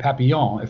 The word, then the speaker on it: Papillon: And the book is called The Hungarian Papillon, or Hungarian Papillon, if you want to pronounce it correctly in French.